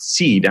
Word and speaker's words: CED: So I set it up as a acronym and pronounced SEED. Actually, there's a macron over the E.